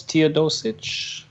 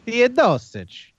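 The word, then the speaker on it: Teodosić: So is it Milos? It's Milos Teodosic. – Teodosic. – With a little weird accent at the end. – Teodosic.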